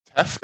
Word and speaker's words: teff: Teff, spell that. – T-E-F-F. – T-E-F-F, teff.